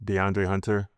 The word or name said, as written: De'Andre Hunter